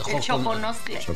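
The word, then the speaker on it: Xoconostle: Choconoxtle. – Sí. Se escribe con X, pero se pronuncia Xoconoxtle.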